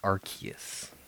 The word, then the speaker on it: Arceus: Next is Pokemon Legends Arceus. – However, I think it's officially pronounced Arceus.